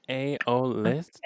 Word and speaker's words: aeolist: Aeolist? – Okay. – Aeolist. – Well, it's like aeolist, like okay. Because of the way it's spelled, so. It's spelled A-E-O-L-I-S-T, but it's like aeolist.